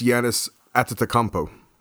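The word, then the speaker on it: Giannis Antetokounmpo: It's Yanis Atetokounmpo. I believe that's how it's pronounced. There's a lot of T's and there's an N and there's a P at the end of it.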